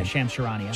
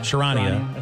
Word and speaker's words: Charania: Yeah, Shams Charania. – Charania, I think.